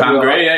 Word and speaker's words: Pam Grier: Pam Grier, yeah.